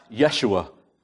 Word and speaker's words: Yeshua: And also, Joshua's name would have been pronounced Yeshua in those days. That's a Hebrew way of saying, Joshua. I don't know if you know or not,